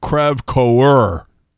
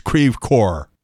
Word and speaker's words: Creve Coeur: It's not Creve Coeur. It's Creve Coeur.